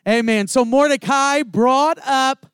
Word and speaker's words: Mordecai: It is actually pronounced Mordecai.